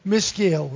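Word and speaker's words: Maschil: Mishael is how you say that, for the sons of Korah.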